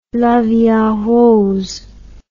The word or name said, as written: la vie en rose